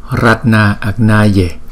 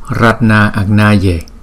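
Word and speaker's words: Ratnāgnaye: Bởi vì sao chúng ta đọc là Ratna Agnaye? Bởi vì chữ Ratna Agnaye là hai chữ Ratna và chữ Agnaye gộp lại, thì hai chữ A nó kế tiếp với nhau thì chúng ta gom lại thành một chữ A có dấu gạch ngang trên đầu.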